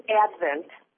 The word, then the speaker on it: Advent: it's Advent, like Christmas Advent.